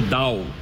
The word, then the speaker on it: doubt: Mon petit Dicky, c'est No Doubt. Doubt en anglais, doute. Ça s'écrit comme ça, avec un B. – D-O-U-B-T, je pense que le B se prononce pas.